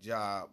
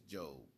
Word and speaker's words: Job: It looks like Job, but it's Job. It's J-O-B. You pronounce it a little different.